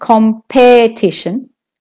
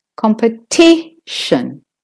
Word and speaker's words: competition: It's not competition. Competition. The com, C-O-M, is a secondary stress syllable. So it isn't as loud or long as the T.